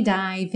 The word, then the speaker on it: dive: Watch as I dive in. Watch as, juntando, I dive in. Dive, o E não é pronunciado, você já junta o V no in.